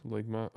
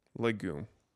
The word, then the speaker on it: legume: Legume.